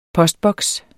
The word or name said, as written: postboks